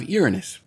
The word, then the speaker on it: Uranus: Then we have Uranus. Yes. It's pronounced that way and not the way you think.